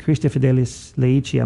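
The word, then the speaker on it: Christifideles Laici: And I think I'm trying to remember what part of St. John Paul's document, Christi Fidelis Laici, I'm not saying it wrong, I'm even saying it right. – That's right.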